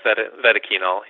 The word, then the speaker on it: Vetoquinol: And I know your company, Vetoquinone, am I pronouncing it correctly? – Yeah, Vetoquinol. Yep. – Vetoquinol.